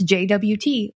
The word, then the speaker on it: JWT: And he was like, it's JWT or jot.